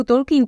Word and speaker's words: Tolkien: No, no preferuju Tolkien, protože je to správně ale uh,